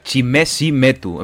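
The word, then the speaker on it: Chimezie Metu: Se pronuncia Chimesimetu, en plan Chime si me, bueno, da igual.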